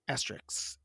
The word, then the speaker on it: asterisk: Asterisks. Asterisk. Asterisk. Asterisk. That's how you say it.